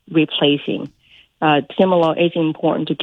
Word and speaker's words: Timolol: I don't even know if I'm pronouncing it right. Can he get off? – Did I do a good job? – Timolol, but Timolol is a beta blocker.